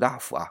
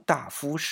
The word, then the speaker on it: 大夫: 那对于刘昌义来说，这是一个很重要的头衔，因为光禄大夫是。散官啊，这里想先说一下啊，啊，往往很多人都会读成大夫，因为看到大夫两个字嘛，呃，在现在我们觉得有文化一点的放在一起都得读大夫，对吧？